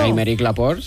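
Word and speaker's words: Aymeric Laporte: Y después, Amèric Laporte. – Sí. – También él es muy curiosito. – Amèric Laporte, sí.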